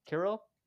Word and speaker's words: Kirill: Kirill.